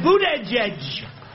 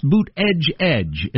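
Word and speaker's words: Buttigieg: it's pronounced Boot Edge Edge, as in the word edge edge. And I thought, that's not the way he says it.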